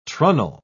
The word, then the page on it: trunnel: British English